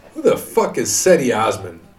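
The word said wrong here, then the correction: Cedi: It's Jetty. I'm serious. Look it up. That's how you pronounce it. Trust me. I said it. It's not right. It's Jetty. Jetty Osman. – So I said Osman right. – Yeah, but it's not Ceddy. It looks like Ceddy.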